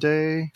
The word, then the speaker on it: Ayoade: Ayoade? Richard Ayoade.